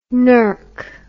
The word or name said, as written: nurk